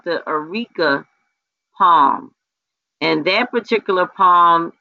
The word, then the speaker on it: Areca: Okay, and that's the Arica. It's spelled A-R-E-C-A, the Arica palm.